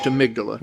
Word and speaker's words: amygdala: You spell it amygdala, but right. – It's pronounced amygdala.